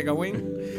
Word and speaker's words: Gawain: El sobrino del rey Arturo Gawain, no sé cómo se dice, se dice Gawain